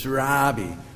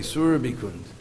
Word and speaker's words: Surbikund: So you notice it's a short A. Everybody says Surabi, but it's short A, Surbikund.